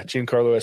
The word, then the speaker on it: Giancarlo: Is that how you pronounce his name? Giancarlo? – Yeah, Giancarlo Esposito.